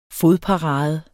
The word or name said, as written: fodparade